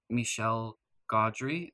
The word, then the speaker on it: Michel Gondry: It is a fantastic movie coming to you from the director, Michel Gondry.